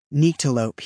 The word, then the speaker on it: Nyctalope: Nyctalope, he said, stressing the long I sound in the first syllable, hasn't toured New Mexico since her first album came out.